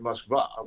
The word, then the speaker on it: Moskva: If people, if people started calling it Moskva, which is how it's actually pronounced mm.